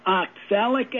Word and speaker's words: oxalic: It's called oxalic acid, and it's spelled O-X-A-L-I-C. And the A-L is pronounced like in the gal's name, Sally, called oxalic acid.